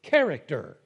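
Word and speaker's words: character (χαρακτήρ): Now, before we go on, that word in the Greek, exact imprint, is actually character. Yes, that's how you say it in the Greek. That's where we get the English word character, right?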